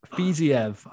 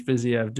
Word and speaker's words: Fiziev: Got your boy, Rafael Fiziev. I learned it this week that it's pronounced Fiziev, Fiziev, not Fiziev. Yeah. Everyone calls him Fiziev. Fiziev. Apparently, it's Fiziev.